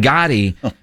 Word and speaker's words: Gotti: And Gotti, the way, is the right way to say it, according to Tyler Guyton.